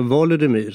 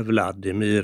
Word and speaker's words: Volodymyr: Och alltså de två mest kända och för eftervärlden berömda storfurstarna, det är ju alltså Volodymyr som kallas för Volodymyr den store. Ryssarna kallar honom då för Vladimir, alltså det ryska uttalet.